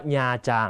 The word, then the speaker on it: Nha Trang: ô của em cũng thích đi Nha Trang นะครับพ่อของน้องก็ชอบไปญาจางคำนี้ต้องอ่านให้ถูกนะฮะอ่านว่าญาจางนะครับเป็นสถานที่ท่องเที่ยวเวียดนามเป็นทะเลที่สวยสุดในประเทศเวียดนามนะครับคนไทยชอบไปอ่านว่านาตรังนะฮะไม่ใช่นะฮะต้องญาจางต่อมานะครับองบากุกเด็กไทยรู้หน้าที่ทำดีไม่ต้องเดียวจากบตด.